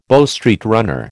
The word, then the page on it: Bow Street runner: American English